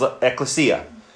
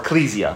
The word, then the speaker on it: ekklesia: The word he would use was ekklesia, or ecclesia. It can be pronounced both ways in the Greek.